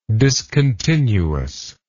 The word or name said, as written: discontinuousness